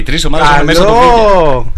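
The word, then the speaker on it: villa: Δεν είναι Βίλη, είναι Βίλια. – Βίλια. – Σεβίλια γράφεται. – Ναι. – Απλά προφέρεται Ιά γιατί είναι double L στην Ισπανία.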